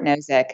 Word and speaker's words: Nozick: And so in the book, you reference, I don't know if I'm going to get his name right, but last name is Nozick? – Yes, Robert Nozick.